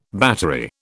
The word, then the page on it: battery: British English